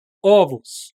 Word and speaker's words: ovos: Ovo, ovos. No plural, você tem que, tem que pronunciar com o aberto.